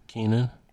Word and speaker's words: Keanon: Is this Keon? How you say his name? – Keenan. – Keenan. Keanu. Keenan? – Yeah.